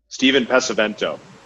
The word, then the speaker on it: Steven Pesavento: Steven Pesavento. – Pesavento. Steven, do you mind introducing yourself to the audience? – Yeah, absolutely. Absolutely. So Steven Pesavento, host of the Investor Mindset podcast, founder and key principal at Von Fitch Capital, a multifamily acquisition company.